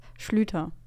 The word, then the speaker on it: Sluiter: Sleuter? Wie wird denn das sonst äh, ausgesprochen? Also so wie ich es immer gelesen habe und gehört bekommen habe, war es Schlüter.